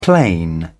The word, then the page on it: plain: British English